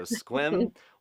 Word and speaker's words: Sequim: She gave me a pronunciation key for that. I've said it wrong all these times. So Sequim, Washington.